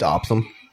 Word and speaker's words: opossum: You ever wondered why it's not pronounced opossum? Just by the way it's spelled, you know what I mean? Sometimes opsum. I like to say that. Yeah, but it's spelled opossum. – It's opsum.